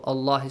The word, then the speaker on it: Allah: Wallahu, Allah is pronounced with a heavy sound, right?